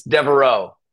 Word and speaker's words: Deveraux: Hey, man, it throws you curveballs with that X. It's Deveraux.